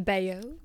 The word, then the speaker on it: Bayeux: Yeah, I feel like it's the Bayeux.